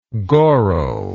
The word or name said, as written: goral